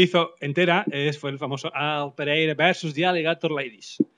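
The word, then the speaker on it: Al Pereira vs. the Alligator Ladies: Se ha de pronunciar así, ¿no? Si no sirve. – Sí, no sirve. Sí, se puede decir Al Pereira de Alligator Ladies, pero no queda bien.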